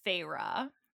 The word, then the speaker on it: Feyre: So our main character's name is Feyre. And we know that's how you pronounce it because a few people listen on audiobook, but also, and I've never seen this done before.